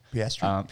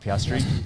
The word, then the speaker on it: Piastri: Our boy Oscar Pistarri Starry. – Piastri. – Piastri.